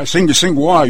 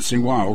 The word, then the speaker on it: Tsinghua: Yeah, Tsinghua, you pronounce it. Tsinghua, okay. – Yes.